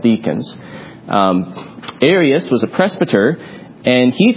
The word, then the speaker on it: Arius: Is it Arius? – Arius, yes. Just one extra syllable. Arius. Arius with an A.